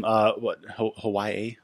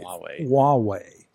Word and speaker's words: Huawei: Yes, them, Hawaii? I don't know the pronunciation. – Huawei, I believe. – Huawei.